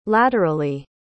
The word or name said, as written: laterally